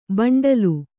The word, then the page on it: ಬಂಡಲು: baṇḍalu